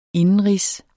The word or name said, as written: indenrigs